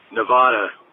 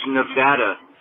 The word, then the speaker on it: Nevada: I'm from Las Vegas, and I just got to say, Nevada. That's not how you say it. It's Nevada. I'm from Las Vegas, Nevada. Been here my entire life. And you'd be skinned alive if you said Nevada here.